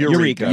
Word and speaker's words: Eureka: Eureka.